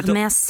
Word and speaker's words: Hermès: Det är av det franska modehuset, jag ber om ursäkt för uttal, Hermès, så man uttalar Hermès. – Inte, ja, man uttalar inte H1 va, i franska.